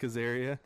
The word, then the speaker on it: Azaria: How do you say his name? Azaria? Frank Azaria. – Hank Azaria.